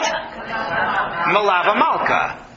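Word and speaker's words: Melaveh Malka: There's some debate as to how to say this. Meaning, depends on whether it's Aramaic or Hebrew. If it's Hebrew, then it's queen. Malka Mem Lamed Kaf He.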